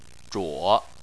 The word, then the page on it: zhuo: 3